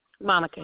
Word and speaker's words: Monica: Monica Harris. – And Monica is spelled M-O-N-I-C-A? – That's correct. – And Harris, H-A-R-R-I-S.